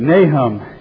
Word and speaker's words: Nahum: You know, about 150 years after that experience, God has been talking about Nineveh again in a book in the scriptures called Nahum. Kind of rhymes with mayhem. But Nahum,